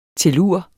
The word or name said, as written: tellur